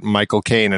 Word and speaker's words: Michael Caine: What is the, you know, the trick in I can't remember the movie, but where they're talking about Michael Caine and how you say his name is you say my cocaine, Michael Caine, and that's like how he says his name.